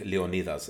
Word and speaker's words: Leonidas: But then you had Leonidas, I'll say it in Greek, Leonidas, that's how you say it in Greek, right?